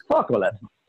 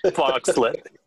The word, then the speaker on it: Fauxolate: That'd be F-A-U-X-O-L-A-T-E. Focklet. – Focklet.